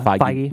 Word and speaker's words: Feige: And then we have, I think, is it Feige? Is that how you pronounce it? – Feige.